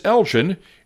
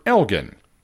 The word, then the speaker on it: Elgin: And a few weeks ago I got Glenmurray right, but mispronounced the name of the town as Elgin instead of the proper Elgin.